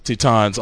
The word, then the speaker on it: Titans: Titans. – Oh, I'm sorry. I slipped into the Japanese pronunciation of it. Titans versus AUG.